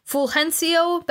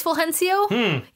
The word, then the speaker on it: Fulgencio: The Golden Telephone presented to Cuban director Fulgencio Batista. Do you know how I know it's Fulgencio? Even though it's spelled weird? Modern Family. Because they named the kid Fulgencio.